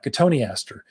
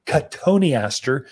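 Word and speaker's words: Cotoneaster: It's a great plant, Cotoni aster. You'd spell it like cotton Easter. So everyone that doesn't know how to say the Latin, but Cotoni aster is how you pronounce that one as you come in.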